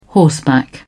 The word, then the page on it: horseback: British English